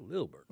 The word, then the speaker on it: Lilburn: I didn't say it like that. Just Lilburn.